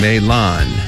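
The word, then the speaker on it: Mahlon: 그럼 먼저 말론을 영어로는 어떻게 발음할까요? 메일란. 한 번 더요. 메일란. 말론을 메일란 이렇게 발음하시고 기련은 킬리안, 킬리안, 메일란, 킬리안 이렇게 연습해 주시기 바랍니다.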